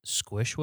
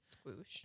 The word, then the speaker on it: Squoosh: Squish would. – Right. – Or Squoosh. I don't even know how to say it. – Squoosh.